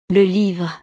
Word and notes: le livre: frances

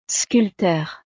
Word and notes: sculpteur: frances

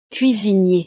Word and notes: cuisinier: frances

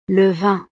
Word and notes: le vin: frances

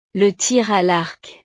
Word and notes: le tir a l'arc: frances

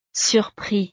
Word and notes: surpris: frances

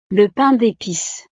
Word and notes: le pain depice: frances

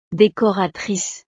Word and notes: decoratrice: frances

